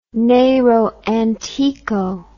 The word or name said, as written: nero antico